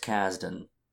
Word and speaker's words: Kasdan: So, the director, Lawrence Kasdan, is that how you say it? – Yes.